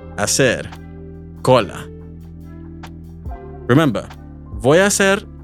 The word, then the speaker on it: hacer: Be aware, however, that because the H sound is silent in Spanish, remember it's hola, not hola, it can be tricky to distinguish the two consecutive A vowels between voya and hacer.